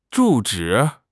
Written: zhu4 zhi3

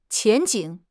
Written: 前景

